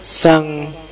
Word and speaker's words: Jean: Oke, kita ketemu Zhang Baudrillard. Ya, mocone agak hati-hati. Tulisannya Jehan, cuma bacanya apa? Kayak Zhan. Ya, Zhan. Bukan Zhong ya, Zhong. Jadi semacam Zhan.